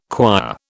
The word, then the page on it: quire: British English